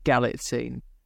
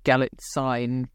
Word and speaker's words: Galitzine: It's got Julianne Moore in it, Nicholas Galitzine, who I watched a bit of an interview with him to see how you pronounce that name, and it was an American saying it, but they said Galitzine. So that's what I'm going with.